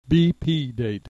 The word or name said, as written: B P date